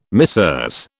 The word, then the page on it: Messieurs: British English